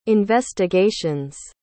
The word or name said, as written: investigations